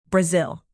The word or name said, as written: brazil